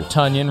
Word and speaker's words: Tonyan: Robert Tonyan rhymes with Funyan.